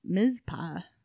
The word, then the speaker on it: Mizpah: Like many establishments founded during the mining rush of early 20th century Nevada, the Mitzvah Hotel was constructed for settlers in 1907. Mizpah. I might not be saying that right. M-I-Z-P-A-H. – Mizpah.